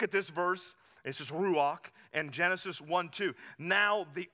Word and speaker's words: ruach: It's ruach. Ruach. You kind of have to say it like you got popcorn in the back of your throat if you want to say it the right way.